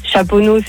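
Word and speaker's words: Chaponost: Chaponneau, c'est ST, tu le prononces pas.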